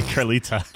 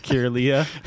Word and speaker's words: Kirlia: There's no T in there, so yeah, I don't think it'd be Kirlita. – Kirlia? – Sure.